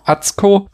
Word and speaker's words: Atsuko: Das wird von Atsuko, wird wie ausgesprochen? Das kann, Atsuko? Atsuko, das U wird nicht gesprochen.